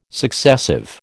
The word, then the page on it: successive: American English